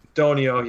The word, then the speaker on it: Donio: Like I stated earlier, they may just, and you know, I'd have to ask somebody from Ryan, you know, maybe Ryan Doganox, or I probably butchered that last name. – Donio, but, yep. – Donio, yeah, I knew I did.